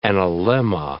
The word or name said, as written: analemma